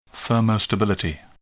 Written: thermostability

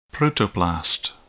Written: protoplast

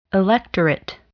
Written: electorate

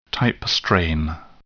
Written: type strain

